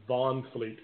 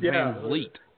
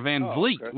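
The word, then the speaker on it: VanVleet: It's actually with a V. It's Fred Van Fleet. – Yeah, not Von Fleet. Fleet. – Yeah, Van Vliet with the V.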